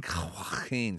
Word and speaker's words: Joaquin: Joaquin Phoenix.